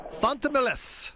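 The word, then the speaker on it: Fontamillas: Jerome Fontemelis.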